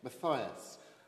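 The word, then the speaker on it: Mathias: I pronounced it wrong again. Matthias.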